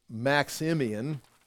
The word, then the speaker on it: Maximian: He's coming down and going to cross the Tiber River and move into Rome, where Maximus is waiting for him. Well, I said the name wrong. I keep saying Maximus. It's Maximian.